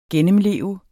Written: gennemleve

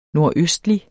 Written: nordøstlig

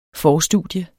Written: forstudium

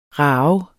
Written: rage

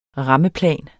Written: rammeplan

